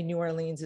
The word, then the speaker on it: New Orleans: And you said, the only time you say New Orleans is when you are rhyming. So technically, and I laughed and I was like, I'm going to take that. – You're rhyming with Cajun Queens.